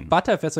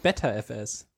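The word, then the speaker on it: Btrfs: Ist ja auch ButterFS und nicht BetterFS.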